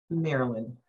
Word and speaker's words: Maryland: How do you pronounce it, being that you are from there? – Maryland.